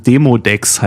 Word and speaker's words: Demodex: Ich habe es falsch ausgesprochen. Demodex heißt es.